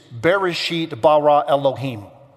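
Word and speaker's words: Bereshit bara Elohim (בראשית ברא אלהים): It is Bereshit bara Elohim. That's how you say it in Hebrew.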